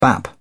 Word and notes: bap: British English

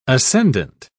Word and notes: ascendent: British English

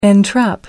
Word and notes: entrap: American English

